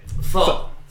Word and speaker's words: pho: Pho.